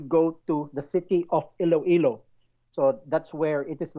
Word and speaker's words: Iloilo: it is pronounced as Iloilo.